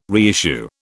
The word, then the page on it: reissue: British English